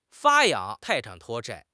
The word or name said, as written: 发扬